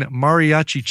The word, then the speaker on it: mariachi: From the album Queen, Mariachi Chill Out or Mariachi Chill Out.